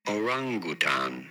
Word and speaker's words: orangutan: Orangutan. You do want to stress on the second syllable, the ran syllable.